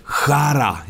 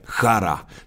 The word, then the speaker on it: Chara: Och sen har man en stark backsida med slovaken Zdeno Chara. Jag frågade här i Slovakien, han är ju omåttligt populär. Hur uttalar man? De säger Chara. Men vi brukar ju säga Chara.